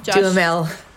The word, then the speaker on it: Duhamel: everyone seems to focus on the Josh Duhamel. How do you say his name? – Josh Duhamel.